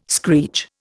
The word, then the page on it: screech: Indian